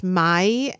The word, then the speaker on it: Maille: I think my parents always kept either Grey Poupon or I think it's pronounced Maille, M-A-I-L-L-E.